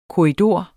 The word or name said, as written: korridor